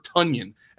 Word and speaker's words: Tonyan: It's Rodgers, it's Adams, it's whoever starts in that backfield, and I think you could take a shot on Robert Tonyan, and that is how you pronounce it. The Packers put out a tweet that said it is just like Funyuns.